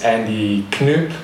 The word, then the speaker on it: Knoop: My guest today is Andy Knoop.